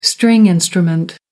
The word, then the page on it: stringed instrument: American English